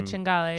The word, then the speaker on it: cinghiale: We had tagliolino, cinghiale. Is that how you pronounce it? – Yeah, cinghiale.